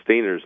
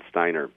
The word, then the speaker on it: Stainer: I mispronounced Stainer's name. It's Stainer, not Steiner, and I was reminded of the fact that C.F.W.